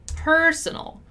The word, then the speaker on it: personal: We have personnel and personal, two different stressed syllables, although the words are very similar.